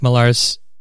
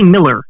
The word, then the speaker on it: Millar: Yeah, it's just hard to say because, and he'll be fine with me saying this. He's pretentious and makes you pronounce it dumb because it should just be Miller, but it's got to be Millar.